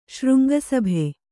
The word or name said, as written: ಶೃಂಗಸಭೆ